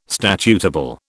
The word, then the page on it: statutable: British English